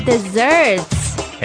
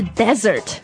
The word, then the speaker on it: dessert: Hi guys, today we're talking about desserts. – Hey, yum yum. – 对，甜品啊，desserts. 那这个读音大家要注意，它是 dessert，不是 desert.